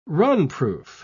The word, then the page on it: runproof: British English